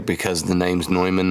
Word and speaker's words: Neumann: It's not a Neumann. It's a Neumann. – No, it's a Neumann to me. – Yeah, they were saying, it took me forever to figure out what they were saying. But they were saying Neumann. It's N-E-U-M-A-N-N, but apparently it's pronounced Neumann.